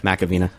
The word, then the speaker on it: McAvena: Macavina.